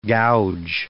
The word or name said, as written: gouge